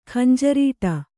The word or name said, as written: ಖಂಜರೀಟ